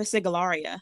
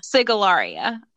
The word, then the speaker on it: Sigillaria: When I pronounce it, Sigillaria. – Sigillaria. Okay. Mm-hmm.